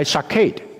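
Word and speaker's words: shakid (שָׁקֵד): 杏树在希伯来文的读音是 shakid，留意保守在希伯来文的读音呢是 shokid，差不多的 shokid shokid。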